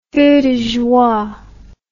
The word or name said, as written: feu de joie